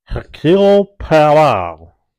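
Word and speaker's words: Hercule Poirot: And his name's Hercule Pierrot.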